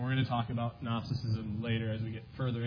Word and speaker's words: Gnosticism: Gnosticism, right? So John is combating Gnosticism, which is spelled with a G for any of you looking it up in the dictionary.